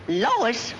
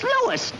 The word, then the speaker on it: Louis: Lois? Me name is Lewis, not Lois. Gee whiz, everybody in Teufel hates me name wrong. It's Lewis. L-O-U-I-S.